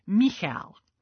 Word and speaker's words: Michal: So she was bad for a moment, and her name is Michal. I know. Honestly, it sounds like you're clearing your throat. It's spelled M-I-C-H-A-L, almost like Michael, but with no E.